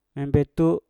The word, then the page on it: embetu: Mpur